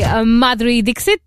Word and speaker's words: Madhuri Dixit: And the artist of the week this week is the one and only Madhuri Dixit.